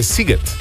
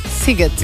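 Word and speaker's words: Sziget: Tukaj nas je nekdo od vas poučil, da se SZ v mađarščini izgovarja kot S, torej je Siget. – Siget.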